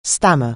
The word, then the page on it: stammer: anglais